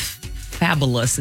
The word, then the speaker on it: Fabolous: She reunited with Fabolous.